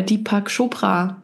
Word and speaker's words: Deepak Chopra: Also ich lese gerade ein Buch von, wie heißt der, Deepak Chopra.